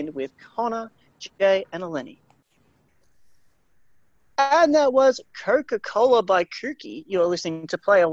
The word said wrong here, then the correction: Cookie: And no, I mispronounced it. It is, in fact, Cookie. It is just Cookie.